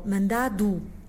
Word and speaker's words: mandado: Esta palavra, portanto, a última sílaba com D-O, mandado, D de Dinamarca.